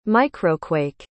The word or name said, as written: microquake